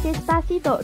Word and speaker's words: Despacito: 歌名 Despacito 中文释义慢慢的 D E S P A C I D O 读作 Despacito。